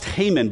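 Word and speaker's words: Heman: It's pronounced Haman, but it's spelled He-Man.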